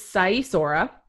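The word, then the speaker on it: Sighișoara: There's many silent letters in that word. I believe it is Saisora.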